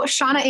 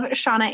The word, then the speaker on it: Seana: So Seana Ann, you can come follow me on Facebook. I'm also over on Instagram at love Seana Ann. And my name is spelled really weird. It's S-E-A-N-A.